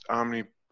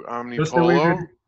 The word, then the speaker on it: Omnipollo: You know, one thing I wanted to bring up is, how do you pronounce Omnipollo? Do you do Omnipollo? – Just the way that you did, I think. – Okay. – Yeah. It's not Omnipollo. That's not Mexican.